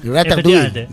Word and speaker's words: ratatouille: Ratatouille. Ratatouille, ¿no? Esa es la pronunciación.